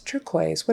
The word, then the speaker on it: turquoise: But then we have words like conquer, conquer, U is silent, turquoise, turquoise, what a pretty turquoise ring you have. Again, the U is silent. Racket, racket, silent U, liquor, and lacquer, both silent U. Now, some words have Q-U-E on the end, and the U and the E are silent.